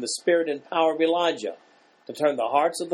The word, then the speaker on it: Elijah: It's a transliteration to Greek and then to English. It's Elijah, is the correct pronunciation.